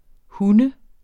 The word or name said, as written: hund